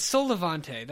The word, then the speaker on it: Sol Levante: And it is called, my pronunciation will be off, Sol Levant, I believe. Have you seen this? – Sol Levante. I don't know what it is. – It's Sol Levante.